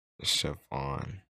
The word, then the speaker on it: Siobhan: When you said it was two syllables, I was so lost. – I had to. – Because the first three letters was already two syllables in my head. I'm like, Siobhan. Oh, yeah, that's not two syllables.